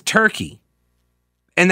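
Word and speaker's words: Türkiye: They would like us to call it Turkeya, because that's how they pronounce it.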